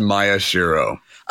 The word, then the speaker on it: Maeshiro: Mike, am I saying your last name right? I can't believe I didn't even ask you before we started recording. – It's actually pronounced Maya Shiro.